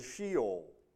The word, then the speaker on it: Sheol: Well, the most common word that is used in the Old Testament is the Hebrew word Sheol, and it's spelt S-H-E-O-L, Sheol.